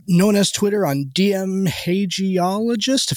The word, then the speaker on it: hagiologist: I usually go with hagiologist.